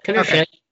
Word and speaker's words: Pinochet: Did I mispronounce Pinochet? – No, you got it. Pinochet.